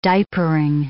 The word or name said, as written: diapering